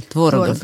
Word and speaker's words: Творогов: У него ударение. Творогов.